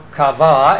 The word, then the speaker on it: qawah: Finally, we have the friend of God, which we would illustrate by the Hebrew word kawa, or kava, as it's pronounced with the German Hebrew pronunciation, Q-A-W-A-H, and that is the word used in Isaiah 40 and verse 31 for waiting on the Lord.